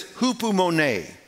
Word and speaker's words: hupomone: In the Greek, it's pronounced this, hupomone. Now, if you look at, it's a compound word. The first part, H-U-P-O,